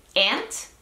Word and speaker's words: aunt: Ambas son correctas, aunque yo he escuchado aunt mucho más frecuentemente. Aunt me suena un poco más como a inglés británico, pero sí tengo una amiga que es de Estados Unidos y dice aunt, así que me gustaría ver como la distribución geográfica de esta pronunciación. Pero cualquiera de las dos es válida y aceptada, depende de lo que a ustedes les guste, así que vamos a practicar ambas.